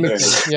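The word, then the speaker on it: McDiarmid: I would feel terrible if I said his name wrong. Please confirm that was correct. – Yes, that's correct. Ian McDermott.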